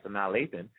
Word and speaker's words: Sanaa Lathan: Sanaa Lathan, am I pronouncing it? Yeah, Sanaa Lathan uh,